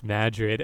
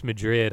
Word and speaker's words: Madrid: Which like they come up to the sign and they see it says, it looks like it says Madrid and it's pronounced Madrid, and John Travolta's like, Spain?